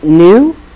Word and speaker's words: nu: You pronounce it nu.